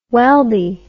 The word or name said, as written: welby